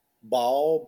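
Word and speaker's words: Baal: My final is Ball. That's just how they pronounced it on. The movie The Rite, the demon that was possessing Anthony Hopkins in The Rite. – Yeah. – Ball.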